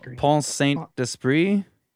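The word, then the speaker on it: Pont-Saint-Esprit: Pont, hold on, I'm having issues with my uh, my Pont, Pont Saint Esprit. Yes. Or Pont Saint Esprit, something like that. Yeah. So, in Pont Saint Esprit, France, were inundated by a large number of patients who were experiencing severe and wide ranging hallucinations.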